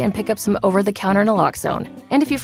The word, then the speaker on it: Naloxone: For the record, it's Naloxone. Nuh, like, is Naloxone addictive? Nuh-uh. Lox, like, Naloxone keeps humans safe, like locks keep bikes safe.